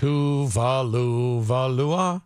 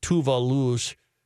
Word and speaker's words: Tuvalu: A University of Auckland study examined changes in the geography of Tuvalu's, I don't know how to say it. – Tuvalu-Valua? – No. The geography of Tuvalu's 9 atolls and 101 reef islands between 1971 and 2014 using aerial photographs and satellite imagery.